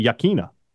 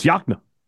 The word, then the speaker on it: Yokena: Subdivision outside of Vicksburg that is spelled Y-O-K-E-N-A. And a lot of people say Yakena, but it's not. It's Yakna.